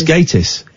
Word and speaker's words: Gatiss: Is it Gatiss or Gatiss? – It's Gatiss.